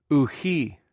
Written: ouci

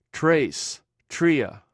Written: treis

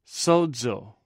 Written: swzw

